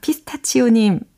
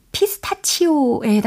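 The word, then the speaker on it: pistachio: 와, 피스타치오님, 반갑습니다. 어, 참고로 이 피스타치오에 해당하는 발음 제대로 한번 알아보는 게 어떨까요? 이렇게 하시면 돼요. 피스타치오. 오, 좀 다르죠? 피스타치오. 피스타치오. 이렇게 하시면 되겠습니다.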